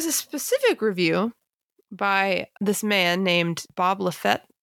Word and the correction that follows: Lefsetz: How would you pronounce that last name? – Lefsetz? – Bob Lefsetz. – Does that sound right? – Yeah, we're just going to call him Bob. – Bob. – Even though we're not on first name basis. Oh, now I have to say it again. Who wrote in his Lefsetz letter. – Lefsetz. I guess it's, I think you're adding some syllables.